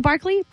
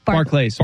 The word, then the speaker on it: Barclays: Is it Barclay? – Barclays. – Barclays. – Like, but is it bank pronounced Barclay Center? – No, Barclays. – No, Barclays. Okay.